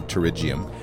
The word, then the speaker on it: pterygium: Also known as pterygium. Pronounced pterygium.